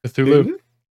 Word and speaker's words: Cthulhu: And you play, it's like based off Cthulhu. I never say it right. – Cthulhu? – Cthulhu, that's the one.